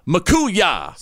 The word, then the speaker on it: Mchooyah: I want you to follow Makuya. – Spell it. – M-C-H-O-O-Y-A-H. Makuya.